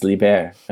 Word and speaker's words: LIBER: I think one of the things that I learned recently at a library conference, Liber or Liber, I think is pronounced in French as Liber,